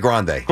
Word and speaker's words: Grande: Ariana Grande. – Grande. Yeah, there you go. Ariana Grande.